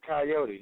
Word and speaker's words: Kyeyote: It's pronounced just like the animal. Actually, it's Coyote, just like the animal. Spelled a little differently, KYEYOTE.